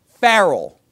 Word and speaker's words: Pharrell: Pharrell.